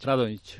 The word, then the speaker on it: Radonjić: Radonjić.